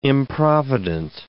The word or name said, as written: improvident